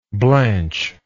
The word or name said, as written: blancher